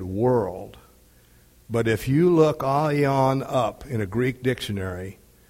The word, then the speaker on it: aion: And the reason for that is the word here, eternal, is the Greek word aion. It's spelled A-I-O-N. It is aion. It's A-I-O-N. Aion.